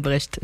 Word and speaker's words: Brecht: C'est Bertolt Brecht. – Bertolt Brecht. – Non, Brecht. – Pardon, Bertolt Brecht.